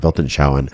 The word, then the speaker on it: Weltanschauung: There's a term, a German term called Weltanschauung, that's how you pronounce it, Weltanschauung, which is like over a long period of time, your cognitive reality over a long period of time.